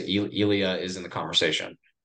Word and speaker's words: Ilya: But Ila, is that how you say it, right? – I think it's Ilya. – Ilya, yeah. Ilya.